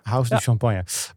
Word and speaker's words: House du Champagne: Ja, en, eh, en, en, en bedrijfsnaam met House du Champagne. Zeg ik dat goed? Of moet ik het Frans uitspreken of zo? Hoe? Nee, het is helemaal goed zo. House du Champagne.